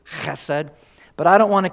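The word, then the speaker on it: chesed (חסד): And the Hebrew pronunciation would be something like chesed with that rough.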